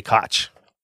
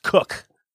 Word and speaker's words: Koch: I always want to say Koch, but it's pronounced Cook. C-0-0-0-K. – Zero-zero? – K-0-0-K-H. – K-C-H.